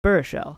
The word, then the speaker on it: Baruchel: Baruchel.